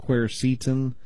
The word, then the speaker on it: quercetin: The other one, yeah, it's a flavonoid that you find in apples and onions called quercetin. That's the way I pronounce it. Some people call it quercetin. I call it quercetin.